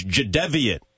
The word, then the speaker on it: Jadeveon: ESPN's Adam Schefter tweets that the correct pronunciation for Jadeveon Clowney's first name is Jadeveon. Second syllable is pronounced like the first syllable in Devin.